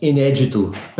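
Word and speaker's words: Inédito: An album that is all but impossible to find by Jobim called Inedito, I-N-E-D-I-T-O, pronounced Inedito. Mm. Inedito.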